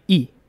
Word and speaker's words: Lee: Ngayon sikat na ang Korean culture, alam na natin na ang tamang bigkas ng L-E-E na apelido ay E.